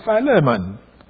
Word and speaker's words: Philemon: Now this sense of Christian virtue, in the Greek word translated faith, is also evident in the apostle's commendation of Philemon or Philemon in Philemon 5.